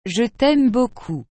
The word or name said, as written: je t aime beaucoup